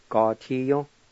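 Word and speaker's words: gatiyo: Number three, stomp grounds, ga-ti-yon.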